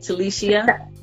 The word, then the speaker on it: Talishia: It's spelled Talicia, though, which is important. I always tell people it's important to say Talicia because they spell my name wrong. – Okay. – When they hear the Ia, they're like, oh, I-A. Oh. Yes, I-A.